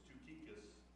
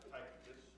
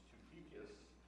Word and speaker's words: Tychicus: And it says, and I'm going to, it doesn't, this is how you pronounce this name. It's Tychicus, not Tychicus or Tychicus, it's Tychicus.